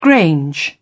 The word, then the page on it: grange: British English